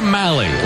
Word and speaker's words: Mahle: Tyler Mahle, last name spelled M-A-H-L-E, and pronounced Mahle, rhymes with rally. Heard a few different pronunciations, and Mahle is the one that he goes with.